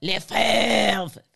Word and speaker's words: Lefebvre: My guests today are actress and comedian Natasha Leggero and chef Ludo Lefebvre. The silent B.